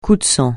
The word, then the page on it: coup de sang: French